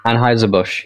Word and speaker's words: Anheuser-Busch: Anheuser-Busch.